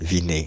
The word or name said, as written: vinez